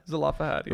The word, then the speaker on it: Zelophehad: how do we end up pronouncing this? Zelophehad? – Zelophehad, yeah.